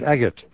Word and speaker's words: Agate: Agate, not Agate or Agate, no. But Agate, Agate Publishing.